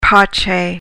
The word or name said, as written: pace